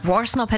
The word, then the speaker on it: Worsnop: Maximum Rock Dark Thoughts, The Funeral Portrait featuring Danny Worsnop. Ist das richtig ausgesprochen? Worsnop, ne? – Worsnop hätte ich jetzt auch gesagt, aber ich finde diesen Anfang so mega.